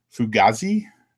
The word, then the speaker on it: Fugazi: Fugazi, did I say that right? – You did.